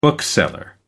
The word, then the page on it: bookseller: American English